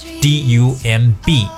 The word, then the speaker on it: dumb: D U M B，而 dumb 这个发音呢，以 m 发音结尾就行了。